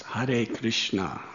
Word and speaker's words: Hare Krishna: I said, it's pronounced Hare Krishna, Hare Krishna.